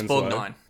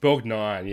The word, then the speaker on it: Borgnine: Shout out to Ernest Borgnine. I'm not sure exactly how you say his name, but he was Ted Denslow. – It's Borgnine. – Borgnine, yeah.